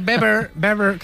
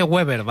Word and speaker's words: Weber: Está Manfred Weber, que se dice Weber, no se dice Weber, ¿vale? Sí, se dice Manfred Weber.